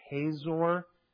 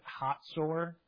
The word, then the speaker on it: Hatzor: There is in the north a city of, you might pronounce it Hazor, but in Israel they would pronounce it Hatzor. The Z is TZ.